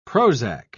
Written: prozac

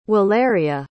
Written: Waleria

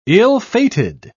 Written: ill-fated

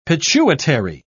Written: pituitary

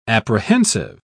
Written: apprehensive

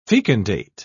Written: fecundate

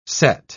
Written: sett

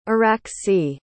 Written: araxie

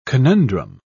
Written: conundrum

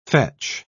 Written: fetch

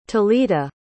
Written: talita

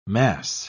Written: mass

